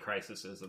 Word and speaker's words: crises: They talk about the crisis, but there's been multiple DC crises in the comic. – That's true. – Crises, if you will, in the comic book. – That is how you say that word.